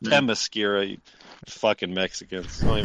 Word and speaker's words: Themyscira: It's T-H. Look, motherfuckers, I've been teaching a kid how to read, right? Okay. I know how to pronounce shit. The T-H is all right? So that's Themyscira.